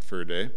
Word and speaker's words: Förde: Förde, F-O-R-D-E, it's spelled Förde, it's pronounced Förde, and he used to teach at Luther Seminary in St. Paul.